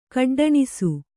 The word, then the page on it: ಗುಡ್ಡೆಣಿಸು: guḍḍeṇisu